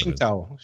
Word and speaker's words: Tsingtao: It's actual Tsingo. This site came up really slow. – No, it's Tsingtao. Tsingtao. – Is that how you say it? Tsingtao? – Tsingtao. I think that's the pronunciation.